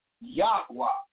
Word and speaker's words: Yahweh: Just don't teach in the name of YHWH, proper pronunciation, Yahweh.